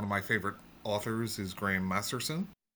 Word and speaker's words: Masterson: Yeah, and one of the audiobooks, the person narrating it got his name wrong at the start, and they said Graham Masterton.